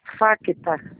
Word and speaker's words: FACTA: Facta. – Isso. FACTA. O C, ele é mudo, tá? – É F.